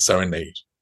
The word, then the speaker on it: Serenade: And I think John Clifford also confirms that for the rest of his life, Balanchine always said Serenade. Mm-hmm. And I think he really thought that it should be pronounced according to the language of the country, how you say the word serenade as in music.